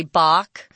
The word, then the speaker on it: Bach: Actually, one that's often used among musicians, at least, is the way that B-A-C-H is pronounced. Most people, everyday people, just say Bach, but it's actually Bach, because it's German.